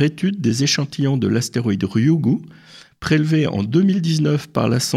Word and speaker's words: Ryugu: Alors on est toujours dans l'actu avec vous Michel bien sûr, et on a découvert les briques de l'ADN sur l'astéroïde Ryugu, je ne sais pas si je prononce Ryugu. – Bien. Parfait, bon accent, merci Michel.